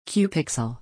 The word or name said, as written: Cupixel